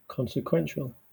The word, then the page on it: consequential: English